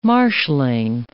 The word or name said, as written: marshalling